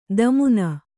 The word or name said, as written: ದಮುನ